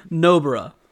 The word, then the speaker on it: Nobara: Is it Nabarro, Nabarre, Nabarra? – Nobara.